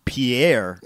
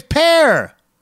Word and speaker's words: Pierre: Now, how about 13 Across, state capital whose name is pronounced as one syllable, not two, as many think. And that's, I guess, what I thought was Pierre. – No, that's not Pierre. It's Pear.